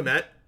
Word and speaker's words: Kmet: Cole Kmet, yeah.